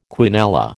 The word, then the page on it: quinella: American English